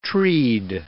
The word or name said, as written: treed